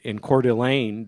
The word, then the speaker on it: Coeur d'Alene: For example, get this, where the men are meeting in Coeur d'Alene. Did I say it right that time, Jack? – You did.